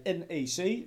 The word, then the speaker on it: NEC: We gaan namelijk op bezoek bij NEC. Zoals je het volgens mij goed moet uitspreken. – Nou, NEC mag ook. – NEC mag ook, oké.